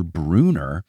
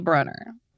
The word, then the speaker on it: Brunner: How do people keep thinking it's Bruner? – I mean, at this point, I gotta think maybe Rick thinks it's Bruner. – And we love Rick, but as he has admitted, he does pronounce his own last name wrong. So, like, maybe he was like, yeah, guys, two N's, but it's Bruner. I don't know. – It's definitely Brunner.